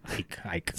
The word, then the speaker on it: Icke: The man that popularized the theory, that's David Icke. – Icke. – Feel free to catch me on that every time I'm always going to say his name wrong.